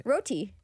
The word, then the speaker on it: roti: Roti. – Shem. – It's roti. – Roti. – Roti. – Yeah. Yeah, but you put too much emphasis on the end.